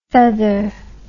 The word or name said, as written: faither